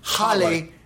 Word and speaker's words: Halle: I think the correct pronunciation because Halle Berry.